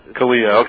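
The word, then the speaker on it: CALEA: Here's something about the fucking, one of my favorites, the Communications Assistant for Law Enforcement Act, Kalia. I guess that's how you would say it. Somebody correct me. Kalia? – Kalia. – Okay, so they want to pronounce it Kalia.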